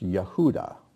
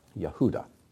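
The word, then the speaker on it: Yehuda (יהודה): And in Hebrew it's pronounced Yehuda. Yehuda.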